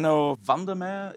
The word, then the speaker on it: Enno Vandermeer: Enno Vandermeer is the Dutch way to pronounce it, CEO of Roon Labs.